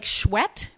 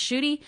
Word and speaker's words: Schuette: And it looks like Schwett, which is how people pronounce it, but it's actually pronounced Schuette.